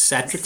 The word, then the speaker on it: Satrix: Satisfaction metrics. Satrix.